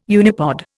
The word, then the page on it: unipod: Indian